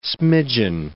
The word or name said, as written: smidgen